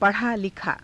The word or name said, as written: pa_rhaa_likhaa